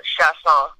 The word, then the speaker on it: Chiasson: So Sha, S-H-A, right? And then Song, Chiasson.